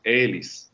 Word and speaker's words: Elis: Tem uma cantora croata chamada Elis, né, com sílaba né, na, na, frente, porque em croata, inclusive, não pode ter palavra oxítona, né, então tem que ser sempre mais para frente.